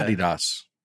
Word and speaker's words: Adidas: Adidas. That's how they say it over there.